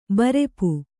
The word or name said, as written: ಬೈರೂಪ